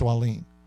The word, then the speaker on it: Wallin: And by the way, that's how you pronounce his name. It's Wallin, for those who want to know.